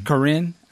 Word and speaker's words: Corinne: And then also, not too long ago, if I'm saying her name right, Corny Bailey Ray. – Corinne.